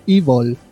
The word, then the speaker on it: Evil: me dice la profe de la British School que se dice Evil.